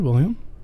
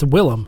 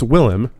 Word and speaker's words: Willem: No, I said William. – It's Willem. – It's Willem. – Is it really? – Yeah. – It's William Dafoe? – This is just the latest in a long line of Sphinx mispronouncing shit. – It's spelled like William.